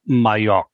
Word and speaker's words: Majok: It's pronounced Majok.